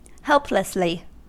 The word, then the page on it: helplessly: American English